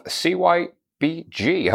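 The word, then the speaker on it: CYBG: Okay, I am here with the one and only David Duffy, who is the CEO of CY BG. Have I said that right? – You have.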